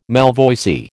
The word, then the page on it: malvoisie: American English